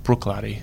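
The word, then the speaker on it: Bruichladdich: I love Brooklady, and it is pronounced Brooklady, for those wondering.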